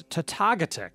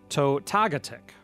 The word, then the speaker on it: Totogatic: It is spelled T-O-T-O-G-A-T-I-C. You dug into this one. What did you find out? – So to look at it, it could be Totogaddick, Totogaddick, but then I looked in our WPR Classic, Wisconsin Place Names book, and it was there. – And the official pronunciation is? – It is Totogaddick. Totogaddick.